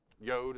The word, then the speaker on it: yod: J-O-D, there's a little mark kind of like that, and it might have that over it. Yod, it's pronounced. It's a Hebrew letter.